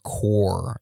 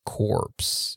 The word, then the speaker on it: Corps: it's pronounced Marine Corps, not corpse. The P is silent.